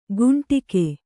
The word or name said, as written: ಕುಂಡಿಗೆ